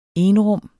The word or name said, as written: enrum